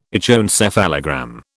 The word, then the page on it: echoencephalogram: British English